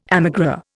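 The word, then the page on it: émigré: Indian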